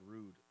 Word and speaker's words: Rhude: His brand is called Rude. It's spelled R-H-U-D-E.